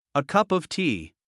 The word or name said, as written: a cup of tea